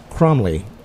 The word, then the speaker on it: Cromly: And to do that, we welcome Father Nathan Cromly.